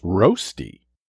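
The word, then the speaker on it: rösti: Today's word is rosti, spelt R-O-S-T-I, rosti, sometimes pronounced roasty.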